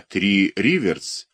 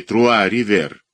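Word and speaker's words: Trois-Rivières: Под Новый год это морской шик, это почести, которые и были возданы капитану Калиостро, мэром городка Три Риверс или Труа Ривер, если произносить, как двояко произносится в Канаде все, по-французски те же три реки.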